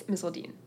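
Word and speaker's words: Misseldine: It's Misseldean.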